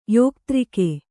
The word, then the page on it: ಯೋಕ್ತ್ರಕ: Kannada